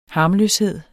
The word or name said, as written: harmløshed